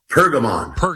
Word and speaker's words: Pergamon: It's called The Altar of, is it Perigmon? Perigmon? How do you say it? – Pergamon. – Pergamon. The Altar of Pergamon.